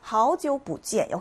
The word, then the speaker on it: 好久不见: 好久不见 역시 마찬가지로 好久 삼성삼성이기 때문에 이성삼성으로 발음하죠. 好久 읽어주면 되고요. 그리고 뿌가 여기서는 성조 변화에서 이성으로 읽힌다는 것이 중요합니다.